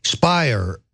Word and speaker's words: Speier: Speier, rhymes with liar.